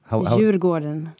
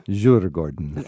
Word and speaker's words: Djurgården: Djurgården is how I would mispronounce it. – Djurgården. – Djurgården. – Yes, perfect.